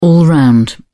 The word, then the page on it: all-round: British English